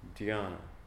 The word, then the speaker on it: dhyana: It's the Japanese voicing of the Chinese voicing of the Sanskrit Buddhist term jhana, dhyana. D-H-Y-A-N-A is how it's spelled out. Or in Pali, they say jhana with a J, J-H.